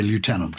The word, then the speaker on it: lieutenant: And I have to address the why is it lef when it's spelled lieu. And the idea, we think, is that someone misread the U for an F because the characters looked very similar centuries ago. And that's why we pronounce it that way, even though we didn't change the spelling. And as you know, Americans say lieutenant, don't they? – They say lieutenant. And so they are closer to the etymology, which is lieu in French, place, and tenant, holding.